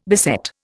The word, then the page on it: beset: Indian